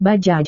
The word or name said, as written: bajaj